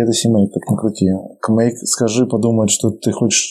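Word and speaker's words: CMake: Кстати, кстати, они его произносят как кмейк, не как симейк.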